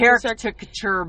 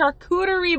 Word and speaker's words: charcuterie: I'm going to have a character board. It's not a character board. It's charcuterie board. – It's a charcuterie board.